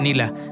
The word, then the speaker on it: Nela: Si la buscan, Nila se escribe N-E-L-A. N-E-L-A, Nela, pero se pronuncia Nila.